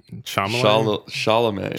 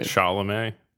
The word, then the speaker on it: Chalamet: Yeah, you're close to saying his name right. – What is it? Chalamet. – Chalamet. Timothy Chalamet.